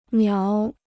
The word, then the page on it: 苗: miáo